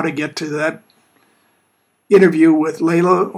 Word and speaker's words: Leila: It's pronounced Layla, but it's spelled L-E-I-L-A, right.